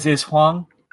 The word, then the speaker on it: Hwang: Yeah. H-W-A-N-G. – Oh, it's, then his, it's Huang. – Is Huang? – Okay. Yeah, Huang.